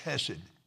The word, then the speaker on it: Hesed: And I didn't know how to pronounce it, but it's Hesed. It's actually Hesed.